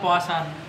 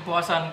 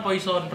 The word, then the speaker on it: Poison: Sudah ada judulnya, judulnya Poasan. – Poasan. – Hmm, poasan. Kalau teman-teman kan bacanya kan poison, racun, Hmm. Bacanya Poasan sebenarnya. Nanti tunggu aja rilisnya.